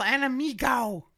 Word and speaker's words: Enemigo: El Enemigo. – You guys both talked down to me about it. You did. – Whatever. – Rarely there's a hard G.